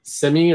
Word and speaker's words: semi: Semi or semi? – I pronounce it with an I. So semi.